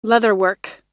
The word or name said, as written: leatherwork